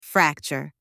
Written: fracture

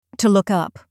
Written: to look up

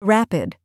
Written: rapid

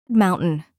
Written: mountain

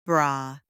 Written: bra

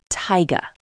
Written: taiga

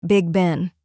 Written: Big Ben

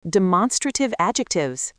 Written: demonstrative adjectives

